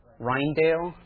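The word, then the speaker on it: Rijndael: I don't know how to pronounce if I'm pronouncing that right. What is it? Rheingel. Okay.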